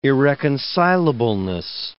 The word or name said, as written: irreconcilableness